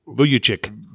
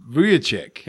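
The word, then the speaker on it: Vučević: How do you say that guy's name for Orlando? Vucevic? – Vucevic. – Vucevic. – Yep.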